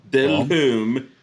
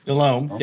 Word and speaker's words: Delhomme: Is it DeLhomme or DeLhomme? – DeLhomme. – I like DeLhomme. I like DeLhomme. – DeLhomme? I don't know. DeLhomme. You got to separate the syllables.